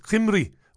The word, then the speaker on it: Cymru: Judson says, you mispronounced Cymru, Wales, recently. It's Cymru, not Cymru.